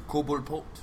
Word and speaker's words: Cobblepot: Mr. Cobblepot. Cobblepot. – Cobblepot. – Cobblepot. – Cobblepot. – That's how you pronounce it in Gotham, apparently.